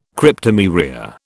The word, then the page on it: Cryptomeria: British English